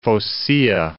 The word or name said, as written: Phocaea